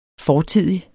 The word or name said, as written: fortidig